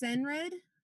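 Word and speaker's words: Cenred: He was in, let's see, He was in Merlin as, I believe it's pronounced Senred. – That's what it is.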